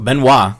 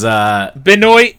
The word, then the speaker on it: Benoit: So Benoit Blanc, I'm pronouncing it correctly this time, is... – Benoit!